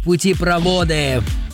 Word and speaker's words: путепроводы: Образованное от двух других, а в таких, как известно, ударение всегда падает на второй корень. А он тут вод. Следовательно, правильно говорить путепроводы.